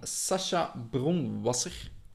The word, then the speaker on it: Sacha Bronwasser: Het is een luister van Sasha Bronwasser. Eh, ik weet niet, we zullen na het lezen zien of ik het juist heb uitgesproken, haar naam. – Perfect.